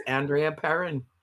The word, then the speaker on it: Andrea Perron: I don't even know. I'm all over. I apologize. – No, you're perfectly right. It's Andrea Perron.